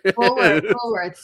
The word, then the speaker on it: Woolworth: I'm mentioning no one knows what Woolsworth is. – Woolworth. Yeah. – Because I was saying it wrong.